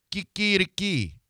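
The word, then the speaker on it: quiquiriquí: No, Q-U-I. – Yeah, key. – Q-U-I. – Key. – R-I. – Kikiriki. Kikiriki. – Q-U-I. – Yeah. Kikiriki. Kikiriki. – K-I. This is all the way. – Kikiriki. – K-I-K-I-R-K-I.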